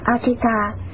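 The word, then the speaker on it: Ajita: Ngài A Dật Đa, tiếng Ấn Độ đọc là Achita, chữ Hán là Vô Năng Thắng, là tên riêng của Ngài Di Lặc Bồ Tát, tiếng Ấn Độ đọc là Makaya.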